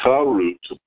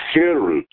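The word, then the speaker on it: cherut (חרות): Do not pronounce it charut, but cherut, which literally means freedom.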